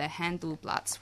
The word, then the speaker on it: Handelsblatt: So according to German's premier business newspaper, the Handelsblatt, which that's what I'm insisting on pronouncing it as, 44% of 673 executive-level German managers surveyed think that Greece should leave the Eurozone of its own accord.